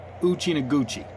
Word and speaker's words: Uchinaguchi: I found 17 different pronunciations for Uchina Uchinaguchi. – Yeah, it, um, I believe Uchi Naguchi. Uchi Naguchi. – Yeah. yeah, it's it's a long A in the middle. That's all. Uchi Naguchi.